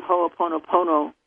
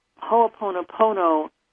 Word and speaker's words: Ho'oponopono: I'm going to suggest the Ho'oponopono way of clearing yourself. And Ho'oponopono And I'll spell that for you. It's H-O-O-T-O-N-O, and then Pono, P-O-N-O again.